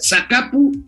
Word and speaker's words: Zacapu: Bueno, pues rápidamente, porque ya se nos hizo tarde, José Fernando Pedraza Zúñiga, no los estoy albureando, amigos, es Zacapu, sin el acento en la U, y es una ciudad a 75 kilómetros de Morelia, rumbo a Zamora.